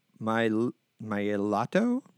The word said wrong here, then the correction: Milioti: whatever, the mother from How I Met Your Mother. – Kristen Milioti? – Milioti.